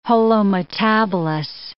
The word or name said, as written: holometabolous